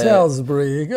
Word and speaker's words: Salisbury: Salisbury, oh, que bela não, pronúncia.